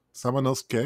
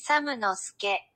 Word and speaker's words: Samanosuke: Samanosuke, because they don't pronounce the U, right? – Yeah.